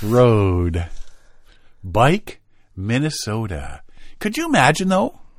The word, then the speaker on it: Buyck: If you're interested, 7236 Far North Road, Bike, Minnesota. Spelt B-U-Y-C-K, pronounced bike, apparently.